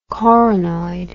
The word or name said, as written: coronoid